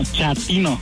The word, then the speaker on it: chatino: Chapino. – ¿Chapino? – No, chatino. – Es como chato, ¿verdad? Chatino. – Sí, así me era como chato, chatino.